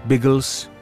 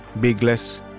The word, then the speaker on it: Biggles: Kort och gott Biggles, eller Biggles, som det i svenskt uttal ofta blev.